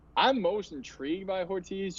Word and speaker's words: Ortiz: 2B of Shane and Horitz. But if you were John Mara. – Ortiz. – Yeah, I butchered that.